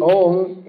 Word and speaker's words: Om: Well, there are three syllables there. A-U-Ma. So these can be understood in different ways. The topmost understanding is that this refers to Krishna, Radha, and all the jivas. Generally, Om is taken as a meditational sound by which one considers oneself to be one with the Supreme.